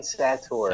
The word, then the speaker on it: Sattaur: Yeah, it's Jehan Sattaur.